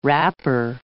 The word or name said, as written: wrapper